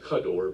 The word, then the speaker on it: kador: And actually, when you look at it, this is technical, but it's actually pronounced kador because the ka in Hebrew means like.